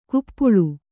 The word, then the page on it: ಕಪೋಳಿ: kapōḷi